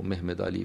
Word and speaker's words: Mehmed Ali: Muhammad Ali, or Mehmed Ali, if you want to choose the Turkish pronunciation of his name, a very able statesman who founded a modern state with no contractual relationship with the people.